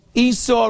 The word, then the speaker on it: Esau: Esau rhymes with hee-haw.